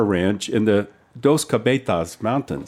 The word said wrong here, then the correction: Dos Cabezas: Dos cabezas.